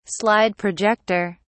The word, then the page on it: slide projector: British English